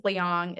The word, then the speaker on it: Liang: But so funny because my name is Liang. It's spelled L-I-A-N-G.